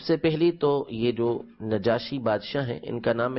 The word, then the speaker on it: نجاشی: عربی کے اندر جو زیادہ فصیح ہے، زیادہ بہتر ہے، وہ نجاشی ہے، نون کے نیچے زیر کے ساتھ کسرہ کے ساتھ نجاشی پڑھنا،